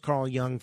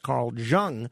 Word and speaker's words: Jung: I now know it's pronounced Carl Jung thanks to Alex Barnard. I used to think it was pronounced Carl Jung.